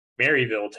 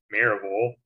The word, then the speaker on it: Maryville: And I've had to give lectures, you know, the past couple of weeks about how it's not pronounced Maryville, Tennessee. It's Maryville.